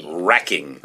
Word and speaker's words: wracking: 네, 그래서 N-E-R-V-E 하이픈하고 W-R-A-C-K-I-N-G. – Racking. – 네, 그런데 종종 보면 W 없이도 쓰더라고요. – Yeah, the W is silent. Anyway, it's nerve-wracking. – 맞습니다. 그래서 N-E-R-V-E R-A-C-K-I-N-G.